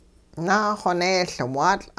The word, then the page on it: naaxoneeLwaatL': hupa